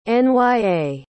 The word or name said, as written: nya